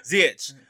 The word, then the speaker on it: Ziyech: Ziyech.